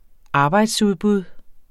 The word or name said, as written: arbejdsudbud